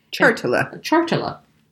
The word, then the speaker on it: chartula: Chartula. – And it's spelled exactly how it sounds, chartula.